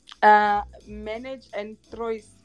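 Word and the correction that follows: Ménage à Trois: Menage and Trois.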